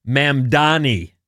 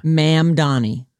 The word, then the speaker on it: Mamdani: Zohran Mamdani was elected mayor of New York City, and that's how you say his name. – Mamdani.